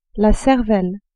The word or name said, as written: cervelle